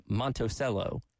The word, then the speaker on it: Monticello: You don't pronounce the T's in Monticello. – It's Monticello.